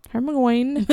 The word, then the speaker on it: Hermione: Yeah, Hermione.